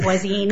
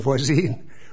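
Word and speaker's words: Voisine: Well, except we haven't applied the Supreme Court's decision, Voisin, I don't know how to pronounce it. – I say it Voisin. – Good, I use your pronunciation, Voisin.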